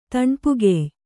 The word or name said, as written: ತಣ್ಪುಗೆಯ್